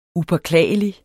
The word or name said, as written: upåklagelig